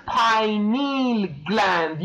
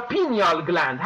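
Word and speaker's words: pineal gland: پاینیل گلند یا پینیال گلند هر دو تلفظ میشه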